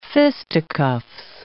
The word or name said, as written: fisticuffs